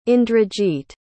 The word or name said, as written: indrajit